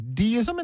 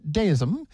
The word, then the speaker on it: deism: Well, there's two pronunciations, deism and deism.